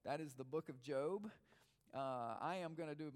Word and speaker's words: Job: His name is Job. It's spelled J-O-B.